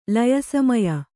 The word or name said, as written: ಲಯಸಮಯ